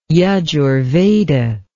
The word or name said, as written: Yajurveda